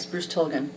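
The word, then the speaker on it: Bruce Tulgan: From, how do you say, Bruce Tolgan? – Yes, Bruce Tilghman.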